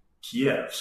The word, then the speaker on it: Kyiv: It's funny because when I've been here in the past, before the war, we said Kiev, so did the locals. – Yeah. – Because but I think it's the Russian pronunciation.